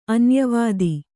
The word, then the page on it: ಅನ್ಯವಧು: anyavadhu